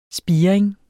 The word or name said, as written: spiring